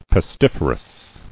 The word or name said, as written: Pestiferously